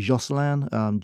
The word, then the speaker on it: Josselin: There's one called, we pronounce it Josselin, J-O-S-S-E-L-I-N.